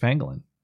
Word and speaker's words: pangolin: he said a bat kissed a penguin and pronounced it weird. No, it was pangolin.